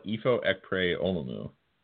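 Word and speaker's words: Ifo Ekpre-Olomu: Ifo Ekpre Olumu. But you were pronouncing it correctly. You were worried you weren't, but you did.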